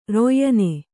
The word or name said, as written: ರಯ್ಯನೆ